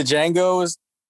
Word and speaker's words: Django: Wasn't the Django's? – Django, sir. The D is silent.